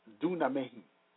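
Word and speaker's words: dunamai: The word dunamis is derived from the word duname, pronounced dunamehi, meaning to be able or to make possible and to be of power.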